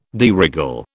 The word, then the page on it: de règle: British English